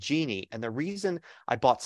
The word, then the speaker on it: Geeni: And Genie, it's spelled G-E-E-N-I, is listed there.